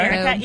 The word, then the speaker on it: Erica: Yes, my Twitter handle is Erica Renee D. – Okay. – Erica, E-R-I-C-A.